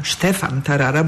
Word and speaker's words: Stefan Tarara: Stefan Tarara, bo tak prawidłowo wymawia się jego imię, przedstawiciel Niemiec, który drugi raz bierze udział w konkursie.